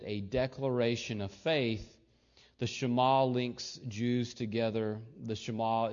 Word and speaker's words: Shema: The Shema, of course, pronounced Shema, is probably the one aspect of Judaism with which all Jews identify.